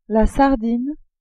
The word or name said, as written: sardine